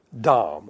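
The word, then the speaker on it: dam: But it's D-A-M. You might also want to know that it's pronounced dom, okay? But anyway, there it is. It's D-A-M.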